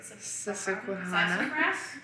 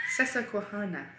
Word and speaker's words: Susquehanna: But it's really Susquehanna. Susquehanna. Yes, Susquehanna Road.